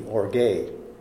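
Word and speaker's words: or gay (ὀργή): The Greek for that, spell it in English, is O-R-G-E, or gay.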